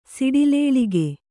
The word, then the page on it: ಸಡಿಲಾಳ್ಕೆ: Kannada